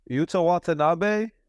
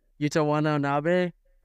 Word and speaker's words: Watanabe: Yuta Wannabe. – Yuta Watanabe. – Yuta Watanabe.